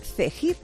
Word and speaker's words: Cegid: Es Cegid, aunque se escribe Cegid con G.